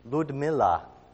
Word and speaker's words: Ludmila: Ludmila is the way you would pronounce her name.